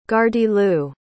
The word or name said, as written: gardyloo